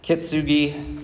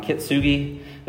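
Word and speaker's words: Kintsugi: Are you familiar with kitsuing? Kitsugi, sorry, I pronounced that wrong. Kitsugi.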